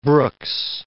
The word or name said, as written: Brooks